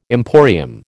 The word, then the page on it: emporium: American English